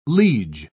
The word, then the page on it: liege: British English